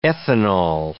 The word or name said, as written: ethanol